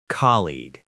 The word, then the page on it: colleague: American English